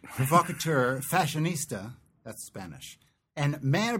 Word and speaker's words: provocateur: Provocateur? How do you say that? – Provocateur.